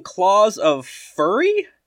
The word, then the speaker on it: Claws of Furry: Um, I tried playing Claws of Furry, not Fury, Claws of Furry, because it looked like an easy 500 or so.